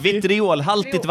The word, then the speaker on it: vitriolhaltigt: Ja, vitrihålig... Hur uttalar man det där? – Får jag se? – Vitrihålig. – Vitriolhaltigt vatten. – Vitriol. Ja. Okay.